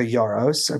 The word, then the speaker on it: Yarros: So the one book in particular that we're probably going to discuss heavily today is Iron Flame by Rebecca Yarros. I believe I'm saying that right. – Yes.